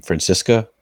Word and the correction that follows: Francesco Akira: Francesco Akira.